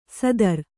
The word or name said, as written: ಸೂದ್ರ